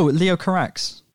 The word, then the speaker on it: Leo Carax: Oh, Leo Carax.